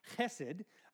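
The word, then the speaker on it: chesed (חֶסֶד): And in verse 13, when it says faithful love, and it's actually from the Hebrew word hesed, or more accurately pronounced, chesed. I don't speak Hebrew, so I'm just going to keep with chesed.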